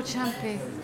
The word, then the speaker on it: Ciampi: And her previous collection, Burnfort Las Vegas, was shortlisted for the Irish Times Poetry Now Award, and she won the 2011 Premio Chiampi International Prize. Am I saying that right? Premio Something Chiampi? Piero Ciampi.